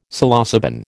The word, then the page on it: Psilocybin: American English